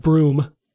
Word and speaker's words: Bruttenholm: It's pronounced Broom.